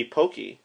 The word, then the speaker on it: poke: Well, it's spelled P-O-K-E. – Yeah. – It could be pokey, like Pokemon. – Yeah, I always said pokey or poke. – You know, I think it's poke.